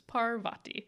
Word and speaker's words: Parvati: Which I wrote down so that no one yelled at us about the pronunciation. – I know. We read Harry Potter as children and formed our pronunciations then, people. So we are learning. – Sorry. We're doing our best. So it's Parvati.